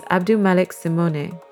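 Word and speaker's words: AbdouMaliq Simone: We would like to clarify that the authentic way to pronounce his name is Abdulmalek Simone.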